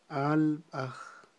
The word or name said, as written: alb'aj